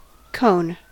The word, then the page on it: cone: American English